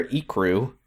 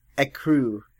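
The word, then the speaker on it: ecru: but she'll wear ikru. – Ekru. – She said ikru. – Yeah, but it's pronounced ekru.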